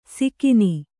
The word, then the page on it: ಸಿಕಿನಿ: sikini